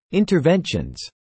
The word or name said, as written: interventions